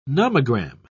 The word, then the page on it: nomogram: British English